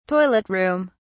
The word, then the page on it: toilet room: British English